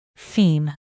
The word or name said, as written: theme